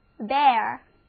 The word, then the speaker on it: bare: 另外有一个词，谈到 bear 就一定要谈它，因为它读起来也是 bear，完全一样的发音，拼写呢也几乎一样，不过意思却完全不同。这就是 B-A-R-E， bear，没有遮盖的，裸露的，光着脚就叫做 bare feet。